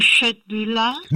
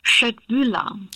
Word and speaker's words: Köttbullar: Nochmal, wie werden die ausgesprochen? – Schöttbühler.